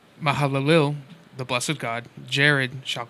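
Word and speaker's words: Mahalalel: Mahalil, the blessed God. – Mahalalil. You missed a syllable in there.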